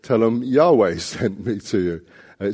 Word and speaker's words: Yahweh: In the English translations, it says Lord in capital letters, L-O-R-D, but that actually stands for the divine name, which as far as we can tell would have been pronounced Yahweh.